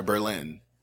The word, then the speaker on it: Berlin: Oh, I'm sorry, Berlin. you gotta say it properly. I've been corrected several times talking about this case. – Really? – With friends and coworkers. They're like, in Connecticut, there's a town called Berlin, but in Germany, it's Berlin.